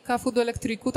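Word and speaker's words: Café do Elétrico: Cafu do Elektriku, tak to się wymawia, bo ja cały czas z językiem portugalskim jeszcze mam problem, ale mam fantastycznych nauczycieli.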